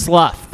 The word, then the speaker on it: slough: and you see another chunk just kind of sloth off. Sloth? Sloth? – Sloth. Pronounced with a... – Slough. Thank you.